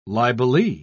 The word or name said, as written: libelee